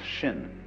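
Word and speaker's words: shin (心): In Chinese, mind is this word pronounced shin, and shin is here.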